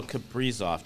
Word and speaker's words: Kaprizov: So Kaprizov, did I say it right? – Yeah.